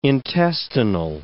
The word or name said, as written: intestinal